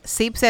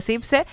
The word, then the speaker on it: Sipse: Así que pues ya saben, si nos quieren escuchar en cualquier parte del mundo, entren a Google, ponen Zipse Play, S-I-P-S-E, Zipse, así se escribe Zipse,